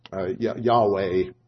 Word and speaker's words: Yahweh: Yahweh, as we would pronounce it. Well, actually, we don't know how to pronounce it because the vowels aren't included in there, just the consonants. We think, to the best of our knowledge, is probably Yahweh there.